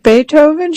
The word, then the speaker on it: Beethoven: Is it Beethoven? She asked once, pronouncing the first part of the composer's name, not Bay, but B, like the insect.